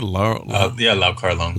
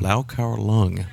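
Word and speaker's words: Lau Kar-leung: How you say his name again? – Lau Kar Lung. – Lau Kar Lung. There we go.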